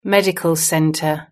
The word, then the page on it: medical centre: British English